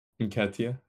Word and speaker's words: Nketiah: Nketiah.